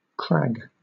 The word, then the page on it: crag: British English